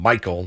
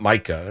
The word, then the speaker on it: Micah: The idea, and you heard Jerry mispronounce Micah's name a number of times, calling him Michael, not Micah, and then corrected himself on one occasion.